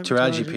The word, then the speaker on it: Taraji: And obviously Taraji, is that how you say her name? – Taraji P. Henson.